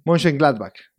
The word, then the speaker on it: Mönchengladbach: Mönchengladbach. Así que lo dije bien. – Mönchengladbach, así.